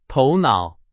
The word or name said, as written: 头脑